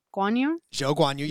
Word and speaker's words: Zhou Guanyu: And it's inspired by Zhou Guanyu. – Zhou Guanyu. Yep. Good job.